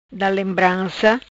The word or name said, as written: Da lembranca